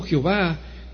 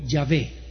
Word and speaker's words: Yahvé: Por lo tanto, los hebreos en la actualidad pronuncian el nombre de Yahweh no como Jehová, pero como Yahvé. YHWH con acento en la E, y no Jehová.